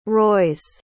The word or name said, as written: rois